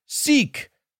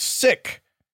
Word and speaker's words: Sikh: The word S I K H is pronounced seek, not sick. As you can imagine, no one wants to be called sick.